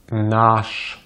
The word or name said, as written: наш